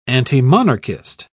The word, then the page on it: antimonarchist: British English